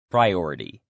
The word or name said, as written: priority